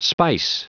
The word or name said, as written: spice